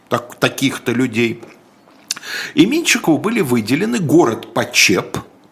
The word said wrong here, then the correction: Почеп: Почеп, прошу прощения, проверил ударение, Почеп, сейчас это Брянская область.